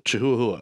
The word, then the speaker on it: chihuahua: Yeah, I do this one for the chahahas. – So, chihuahua, I believe it's pronounced.